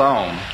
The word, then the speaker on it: Om: The A, well, when one pronounces this syllable, it starts in the back of the mouth, ah, oo, mm, om.